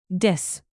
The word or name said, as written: diss